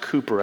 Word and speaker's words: Cowper: It looks like it's spelled Cowper, but it's actually pronounced Cooper, as far as I know.